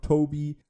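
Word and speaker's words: Tobe: Ja, ich bestehe auf Toby, auch wenn er mit einem E geschrieben wird, denn Toby ist die korrekte Aussprechart.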